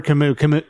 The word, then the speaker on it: Camus: Albert Camus. Camus. – Oh, I always pronounced it Camus. I didn't know it was Camus. – Well, I think it's French. – Sure. – So I think they say Camus.